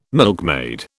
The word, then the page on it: milkmaid: British English